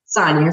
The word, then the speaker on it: Sonya: Am I pronouncing your name correctly, Sonya? – Sonya is fine.